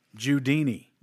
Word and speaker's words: Jewdinee: About Judany, the great music man. Judany? – Spell it. – J-E-W-D-I-N-E-E. – Judini. – The Judini? – Yeah.